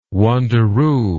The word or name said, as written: wanderoo